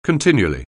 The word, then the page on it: continually: anglais